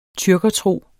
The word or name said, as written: TYRKERTROS